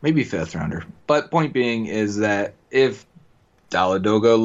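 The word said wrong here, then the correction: Dolegala: looks like the... – Dolagala. – Thank you. Doesn't matter when he earns his name, I'll say it correctly.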